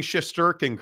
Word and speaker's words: Shesterkin: a good friend of mine is congratulating me on pronouncing Shesterkin correctly.